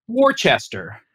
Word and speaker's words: Worcester: Nothing in Massachusetts is pronounced like it's spelled. – Right. – For instance, the city right smack in the middle of Massachusetts, Worcester, looks like Worcester or something. – Yeah, is it Worcestershire? – It's Worcester.